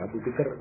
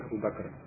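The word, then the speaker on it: Abu Bakr: Bacanya salah keliru, Abu Bikr, bukan Abu Bakar.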